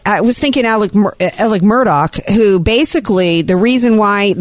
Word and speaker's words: Murdaugh: I've been watching the Murtalk, It's Alex Murdaugh, but it's pronounced Alec Murdoch, and that's because there's a lot of southernness going on in this trial because it's in South Carolina.